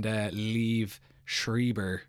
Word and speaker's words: Liev Schreiber: The other big actors that Wikipedia is telling me about are Elle Fanning, Diego Luna, and Liev Schreiber.